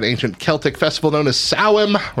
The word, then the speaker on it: Samhain: Ostara, Lammas, Beltane, Beltane, Beltane, Samhain. I don't know any of so them. So that last one is actually pronounced, despite how it's spelled, it's pronounced Sawum.